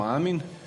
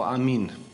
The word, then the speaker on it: amin: To je reč koju mi obično izgovaramo Amin, koju bi pravilnije bilo izgovarati, dakle kako se u originalu izgovara, kao Amin, sa tim naglaskom na posljednjem slogu.